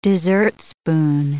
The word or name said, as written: dessertspoon